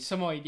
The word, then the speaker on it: Samoyed: Samoyed, yes.